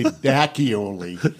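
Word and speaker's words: D'Acchioli: If you could say Ravioli, you can say Dacchioli.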